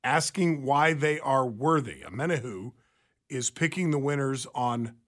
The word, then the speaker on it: Omenihu: Sam, help me pronounce this guy's last name. – Omenihu. – Omenihu, thank you.